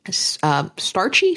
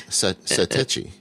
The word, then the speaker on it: Satechi: And apparently there is now, and she points us to, and I'm going to pronounce this wrong, starchy, screechy? – No, it's Satechi. S-A-T-E-C-H-I.